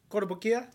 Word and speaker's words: Kotobukiya: Kotobukiya? – Did I say that right? – Yeah, you did well.